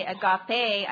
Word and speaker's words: agape: Now, agape, A-G-A-P-E, which I'm told is a longing. So really, although I say agape, I've always said agape, I'm told it's agape. So I'm going to try to pronounce it the correct way, but is used for a higher type of love, a love that is all-absorbing, a love that completely dominates one's whole being.